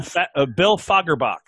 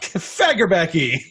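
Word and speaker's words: Fagerbakke: How do you pronounce his last name? – Bill Fagerbach. – Fagerbach. Fagerbecky. – I think it's Fagerbach.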